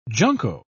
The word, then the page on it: junco: British English